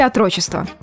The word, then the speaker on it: отрочество: Отрочество или отрочество? Давайте запоминать. Отрочество в современном русском языке требует ударения на первый слог.